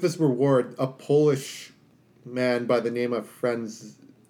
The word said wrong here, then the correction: Franciszek: Franz. I'm gonna butcher this. I'm so sorry, people. – It's that one, Franciszek. Franciszek.